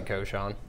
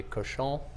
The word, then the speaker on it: cochon: How do you, is it cochon? What are we? – You pronounce it cochon. – Cochon? – Yeah. – Okay. – I guess in French it would be cochon, but that's a little bit soft on the palate for the South.